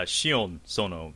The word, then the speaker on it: Sion Sono: Did I say that correct? Uh, Shion Sono is is how I, I think it's pronounced. Yeah. – Okay, that sounds better.